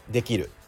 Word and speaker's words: できる: まず正しいイントネーションはできるですできるね、えー、にアクセントが来るとともに下がるんですよね、